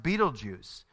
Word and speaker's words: Betelgeuse: The star is Betelgeuse, or sometimes pronounced Betelgeuse.